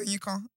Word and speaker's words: Oyinkan: How do you say it? Is it not Oyinkan? – No, it's Oyinkan.